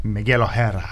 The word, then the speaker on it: Miguel O'Hara: Miguel O'Hara. – Yep, that's how it's pronounced.